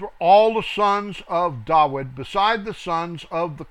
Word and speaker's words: David: I keep saying David. I was raised with that, and it's hard to break that habit because I was raised with a mispronunciation, as were almost everybody. Nobody really knows it's Dawid.